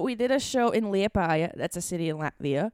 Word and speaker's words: Liepāja: Liepāja. – Liepāja.